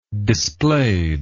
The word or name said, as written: displayed